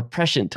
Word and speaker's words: prescient: Prescient or prescient? – Prescient. – Prescient. Yep. Okay. – Actually, I believe both are acceptable.